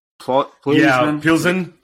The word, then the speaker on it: Plzeň: I feel quite bad for, if I can pronounce the name right, the Czech team, like Plzeň. – FC Victoria. – Yeah. – Plzeň, FC Victoria.